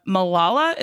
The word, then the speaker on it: Malala: Another piece of news is the Miss Marvel Twitter account posted a handwritten letter from Malala. Is that how I say her name? – Mm-hmm.